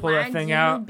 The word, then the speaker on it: wagyu: How do you say it again? – Sushi better introduce me to Aquaman shit. – Ragu? – Wagyu. – Wagyu is a red sauce.